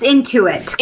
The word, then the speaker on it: Intuit: Is that how you pronounce it? Inuit? – It's Intuit. – Intuit. Okay, there you go.